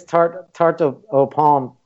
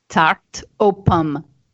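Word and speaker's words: tarte aux pommes: And I would say this tarte au pomme. How do you pronounce it again? – Tarte au pomme. – Tarte au pomme.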